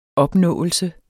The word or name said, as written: opnåelse